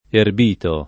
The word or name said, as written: erbito